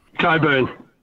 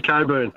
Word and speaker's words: Cockburn: C-O-C-K-B-U-R-N. – Yes! And what does it sound like, Rog? – C-O-C-K. – I don't care. – That's cock to me. No. How would you say it? How would you say it, Roger? – Coburn. – Coburn, Bill. – You sure? – I'm on the radio, Bill. I say Coburn.